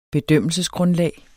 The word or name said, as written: bedømmelsesgrundlag